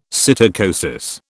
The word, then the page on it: psittacosis: British English